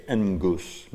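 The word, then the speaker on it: engus (ἐγγύς): Whenever you have two G's together or two gammas together in Greek, you have an N in front of it. You pronounce an N, so you say engus, meaning near or soon.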